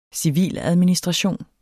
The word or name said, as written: civiladministration